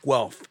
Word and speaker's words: Guelph: And the town is actually pronounced Guelph. – Yeah. – Just like it's on paper. – Tropical city. – I was always Guelph.